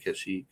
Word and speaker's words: Kashyyyk: I think it's how you pronounce it, Kashyyyk.